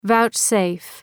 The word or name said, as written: vouchsafe